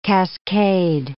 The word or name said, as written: cascade